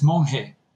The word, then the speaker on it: Monje: Is it Monet? How do I say your last name, Oscar? I don't want to get it wrong. – Oh, no problem. It's Monet.